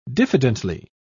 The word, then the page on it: diffidently: British English